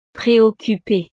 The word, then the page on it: preoccupee: frances